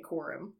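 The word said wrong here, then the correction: Kurim: No. Curum? Curum? – I'm pronouncing it Curum.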